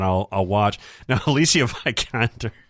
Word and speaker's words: Alicia Vikander: We've on this podcast, we have discussed how this name is pronounced many times. I finally found her variety thing where they ask Google questions and all that, and they always tear off the thing. And it's Alicia Vikander.